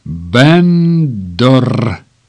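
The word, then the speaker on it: Bender: «Женщина, которую видели в парке Гонолулу, Алекс Бендер, причем Бендер – фамилия по мужу, произносится как Бендор».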